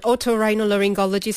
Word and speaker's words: otorhinolaryngology: Dr. Shahir is an otorhinolaryngology specialist from Hospital Kuala Lumpur.